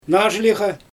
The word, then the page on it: naazhliGa: Xyzyl